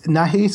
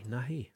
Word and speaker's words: Nahai: It's Nahe. So that's Nahai.